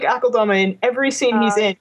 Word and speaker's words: Akeldama: You pronounce it the way I pronounce it, which is Akeldama.